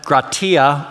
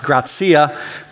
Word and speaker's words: Gratia: And as just discovered from the audio recording, we are in a series entitled Sola Gratia, or can be pronounced Grazia, Grace Alone.